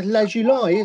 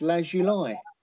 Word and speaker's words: Lazuli: Oh, yeah, Lazuli, isn't it? It's just right English, isn't it? It's correct English. Lazuli.